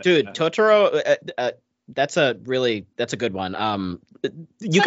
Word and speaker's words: Totoro: Pianist, In the Name of the Father, City Lights, The Life of Brian, My Neighbor Totoro.